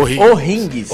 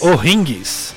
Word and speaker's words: O'Higgins: Mas como é a pronúncia certa? O'Ringues. – O'Ringues.